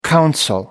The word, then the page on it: counsel: American English